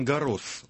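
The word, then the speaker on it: Garros: А это Ролан Гаррос, он все-таки Гаррос читается, французы читают имя летчика. Да-да-да, Гаррос все-таки. Да-да, читают S на конце.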